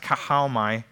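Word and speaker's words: kahalmai (καυχάομαι): And the word there in Greek is pronounced kahalmai,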